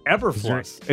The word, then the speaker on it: Eberflus: Eberflus. It sounded like you messed that up, but that's actually how it's spelled.